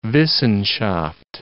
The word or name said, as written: Wissenschaft